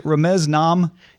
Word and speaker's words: Ramez Naam: Is it Ramez Naam? – You nailed it, Tom.